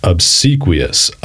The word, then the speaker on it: obsequious: Obsequious. Obsessed. And then the Q-U-I part, qui, rhymes with knee.